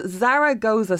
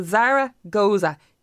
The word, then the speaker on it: Zaragoza: So just for anybody, it's spelled Zaragoza. So think of the shop, the clothes shop Zara. – Yeah. – So Zaragoza, G-O-Z-A.